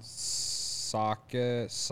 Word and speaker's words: Sochacki: Shout out to Ron Sochaki. I don't know. – How do you spell it? – S-O-C-H-A-C-K-I. – S-O-C-H. – Yeah, I think you got it right. That sounded right to me.